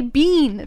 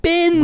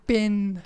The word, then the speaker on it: bin: You know, one thing that's always bothered me is how you say bin. You say bean. That's not bin. Bin. – We've been over this. – Oh my goodness, it's bin. – You see, can you spell bean for me? – B-E-A.